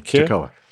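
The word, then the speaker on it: Toccoa: I know how to pronounce that one, Tocoa.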